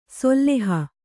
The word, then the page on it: ಸೊಲ್ಲೆಹ: solleha